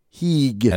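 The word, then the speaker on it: higue: Well, it's a French colony, so I'm going to go with like kind of a Cajun pronunciation. It's a H-I-G-U-E. Heeg. – And O-O-O-L? – O-L-E.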